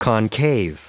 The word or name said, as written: concave